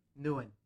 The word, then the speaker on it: Nguyen: It's, I'm saying it right. Nguyen? – Nguyen.